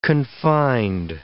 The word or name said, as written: confined